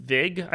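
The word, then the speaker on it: Wiig: And I know Kristen Vig, I think is actually how you pronounce her name.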